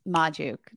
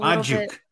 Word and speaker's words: Magdziuk: You're very close. Magdziuk. Just a Magdziuk. A little bit more on the A, and then we're like there.